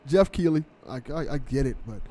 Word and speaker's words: Keighley: Jeff Keighley.